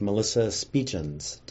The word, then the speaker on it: Melissa Speetjens: Today we're going to be talking with Melissa Speetjens.